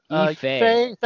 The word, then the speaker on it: Yifei: Who's played by Liu Yifei.